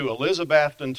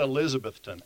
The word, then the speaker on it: Elizabethton: North American Rayon hired him to come to Elizabethton, Tennessee. Now, get that pronunciation. Not Elizabethton, Elizabethton, Tennessee, to run the commissary.